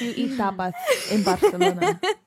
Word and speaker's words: Barcelona: It's pronounced Barcelona. Barcelona.